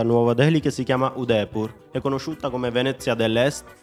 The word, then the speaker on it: Udaipur: Udaipur.